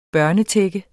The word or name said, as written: børnetække